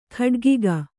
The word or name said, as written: ಕಾಡಕಾಗೆ